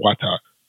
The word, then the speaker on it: Wata: In the late 15th century, young Mohawk siblings, Kahore and Wata, did I say that correctly? – Yes.